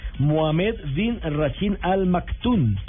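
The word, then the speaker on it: Mohammed bin Rashid Al Maktoum: Mohamed bin Rashid Al Maktoum.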